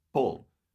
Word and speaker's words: Paul: Paul. Paul? No, Paul. Like, Paul? Cora says to me the other night, she's like, you get like weirdly feminine when you say your name in a Canadian accent.